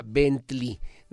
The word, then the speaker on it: Bentley: el negro es un Doberman y se llama Bentley, se, se pronuncia Bentley,